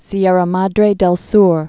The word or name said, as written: Sierra Madre del Sur